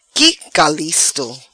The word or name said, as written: Qui calisto